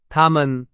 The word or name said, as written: 他们